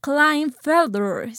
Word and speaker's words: Klinefelter: Eh, vamos a la primera enfermedad que es el síndrome de Kleinfelder. Se escribe Klinefelter.